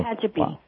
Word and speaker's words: Tehachapi: And Tehachapi, California. Am I saying that right? – Yeah, pretty close. – Oh, Tehachapi.